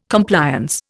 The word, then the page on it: Compliance: Indian